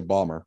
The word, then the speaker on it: Baltimore: People from there say Balmer, but the rest of us humans in the United States usually say Baltimore.